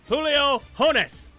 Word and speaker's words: Julio: Some guy takes Julio Jones first round, and some people snicker, and then somebody leans over to him and says, dude, the J is silent in Julio. And the guy that took him goes, oh, okay, Julio Jones.